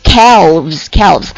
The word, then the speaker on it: calves: 好，下面这个小腿或者是牛肉或者是某种动物的幼崽 calf，它变成复数之后是什么，把这个 F 变成 VES，读作 calves。